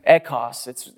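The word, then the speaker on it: echos (ἦχος): I don't speak the language or anything, but it's echos. It's E-C-H-O-S.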